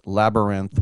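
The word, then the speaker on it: Labyrinthe: And that's Labyrinth with a silent E at the end.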